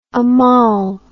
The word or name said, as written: amal